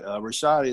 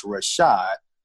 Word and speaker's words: Rashoid: So in my bio on our website, I have in parentheses that is pronounced Rashad, Rashad, because sometimes people say Marquis Rashoid, but it's Rashad.